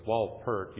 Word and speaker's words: Wolpert: I always have a hard time pronouncing his name, but it's Wolpert.